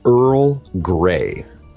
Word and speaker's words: Earl Grey: Earl Grey。